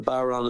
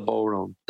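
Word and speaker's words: bodhrán: The Baron is the drum you play, not the Boron.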